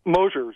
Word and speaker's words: Mosher: And, of course, our guest today, Randy Mosher, last name spelled M-O-S-H-E-R. And am I pronouncing it right? I hope I am. Mosher. – Mosher is normally what? – Yeah, Mosher.